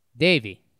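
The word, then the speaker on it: Deivi: How do you pronounce that first name? Davey? – Davey.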